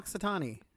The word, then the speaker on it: Punxsutawney: Punxsutaw. – Oh, Punxsutawney.